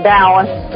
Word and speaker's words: Taoist: Taoist. D O W. You say it like it's a D. Taoist. It's actually pronounced spelled the T, but it's pronounced with the D.